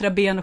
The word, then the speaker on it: Rabén: För att vi uttalade raben, rab, förlåt, Rabén och Sjögren fel.